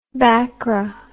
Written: bakra

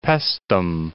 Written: Paestum

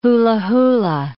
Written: hula-hula

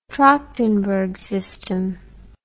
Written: trachtenberg system

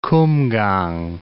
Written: Kumgang

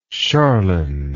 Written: charlyne

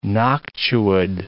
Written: noctuid

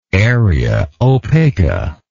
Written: area opaca

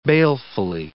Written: balefully

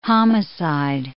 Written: homicide

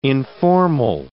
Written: informal

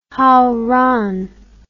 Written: Haoran

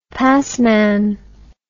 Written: passman

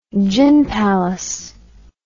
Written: gin palace

